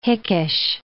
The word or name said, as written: hickish